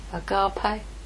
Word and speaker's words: Agape: So if I give him my Eros, he will give me his Agape. Agape? Thank you.